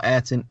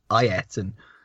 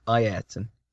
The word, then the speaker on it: Ayrton: So if your kid is called Ayrton, your kid has got to say, no, it's not Ayrton, it's Ayrton. – Is that how it's actually pronounced, is it? And it's pronounced Ayrton.